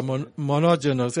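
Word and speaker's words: monogenos (μονογενής): Yo estaba viendo esa palabra engendrado en el Nuevo Testamento, y viene, es la palabra griega monogenos, y es se escribe como monogenes, es una palabra monogenos, es como se pronuncia y significa solo engendrado, el único que está naciendo o brotando.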